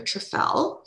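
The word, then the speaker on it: Trufelle: So please follow me on Instagram, Desire Trefel, spelled DesireTruFelle. So Trefel, it stands for truffles for her, Trefel. So how a good way to remember is I fell for Trefel. That's how we pronounce it.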